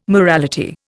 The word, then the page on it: morality: Indian